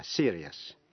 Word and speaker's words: serious: Khi ở trước I-O-U-S là phụ âm như C, T hay X, nghĩa là những phần C-I-O-U-S hay là T-I-O-U-S hay là X-I-O-U-S phát âm là shush. Thí dụ như trong chữ cautious, cautious, ambitious và anxious. Còn nếu trước những tiếp vĩ ngữ IOUS không phải là phụ âm C, T hay X phát âm thành hai âm tiết là ES. Thí dụ, serious, đọc là serious.